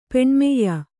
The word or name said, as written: ಪೆಣ್ಮೆಯ್ಯ